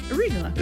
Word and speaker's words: arugula: The island of Santorini is part of Greece, and arugula. Is that how you say it? – Arugula.